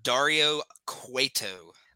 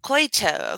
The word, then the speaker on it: Dario Cueto: Can you pronounce his name for me real quick? – That is Dario Cueto. – Cueto, okay, because...